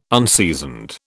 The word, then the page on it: unseasoned: British English